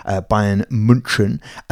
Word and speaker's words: Bayern München: Yeah, they got battered 7-1 by Bayern München, pronouncing it in the German way.